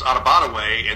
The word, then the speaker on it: Adebawore: Northwestern's defensive tackle, Aditamwa Adibara, I think I've got it close. – Coach Douglas, when he was in high school, said it was Adibadaway.